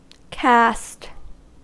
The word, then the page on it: caste: American English